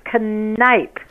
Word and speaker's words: Kniep: No, it's Kniep. You're pronouncing Kniep. – You're pronouncing it wrong, ma'am. – It's Kniep. – No, you're totally wrong. Like, what country are you from? – Germany. It's German.